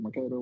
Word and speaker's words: Mercator: So, like, Mr. M is in charge of Mercator. Is that how you say it, Mercator, right? – Yeah.